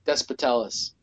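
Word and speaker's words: Despotellis: And Despotalus or whatever. – Despotalus. – There you go. That's how you say it.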